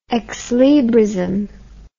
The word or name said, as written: exlibrist